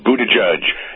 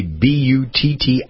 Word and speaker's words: Buttigieg: he spelled his name so it would be Buttigieg. So we would pronounce it. In what universe is G-I-E-G? Hello, try it again, Doug. G-I-E-G. How does that come out as judge?